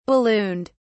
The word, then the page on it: ballooned: British English